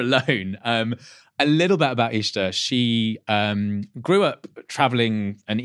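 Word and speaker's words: Ixta: I'm going to let you tell me how to pronounce her first name. – This is, I think I might be saying it wrong, but it's Ishta Belfrage. – Ishta.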